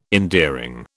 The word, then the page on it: endearing: British English